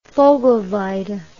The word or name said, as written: vogelweide